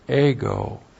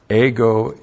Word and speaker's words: ego: And then the personal pronoun ego, ego in the plural.